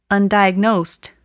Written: undiagnosed

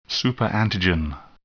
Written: superantigen

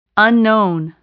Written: unknown

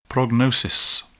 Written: prognosis

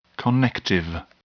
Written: connective

